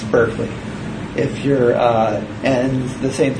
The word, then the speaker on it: Berkeley: So it's pronounced, the first syllable is like bark like a dog. So remember, it's Berkeley, not Berkeley.